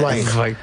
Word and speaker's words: Zweig: Sí, pero desde que el bardo nos ha llevado por el camino bien, ahora hay que decir Zweig. – Es así como se pronuncia en alemán.